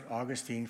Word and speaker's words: Augustine: I pronounce it Augustine for this reason. His name in Latin was Augustinus, A-U-G-U-S-T-I-N-U-S. And all nouns that I know that end in N-U-S in Latin, like Constantinus or Palestinus, there's a number of them, we all anglicize by just putting them into "-ine."